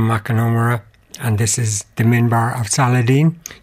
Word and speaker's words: minbar: Because I sometimes mispronounce it as the minibar, and it's not the minibar.